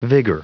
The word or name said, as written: vigour